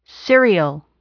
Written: cereal

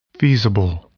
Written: feasible

